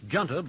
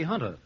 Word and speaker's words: junta: Well, it says the J-U-N-T-A, and I think that's pronounced Junta, but I think it ought to be Hunter.